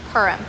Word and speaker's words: Purim: Purim, there it is. Put in an extra syllable, Purim.